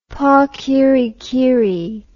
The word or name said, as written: pakirikiri